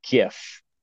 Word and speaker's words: GIF: That would be a GIF. Like a gift without the T.